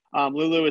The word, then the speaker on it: Loulou: Lulu is spelled L-O-U-L-O-U.